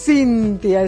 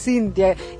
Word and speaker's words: Cintya: Pero es Cintia, es Cintia. Y sabés que se escribe complicadísimo, CYNTH y latina.